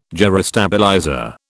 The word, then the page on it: gyrostabilizer: British English